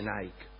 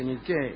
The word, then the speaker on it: Niké: No se dice Nike. Se dice Niké, y por eso se llaman así las zapatillas.